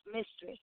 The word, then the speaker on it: Myztress: It's of course, it's pronounced Myztress, but it's not spelled that way.